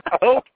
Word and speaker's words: Koch: I just wanted to hear Weeks try to pronounce cock again. – It's Koch, damn it.